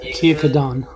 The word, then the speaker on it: Kiyakadan: Kiyakadan. That's how they pronounce it. Kiyakadan. That's harder. Not the way it's spelled.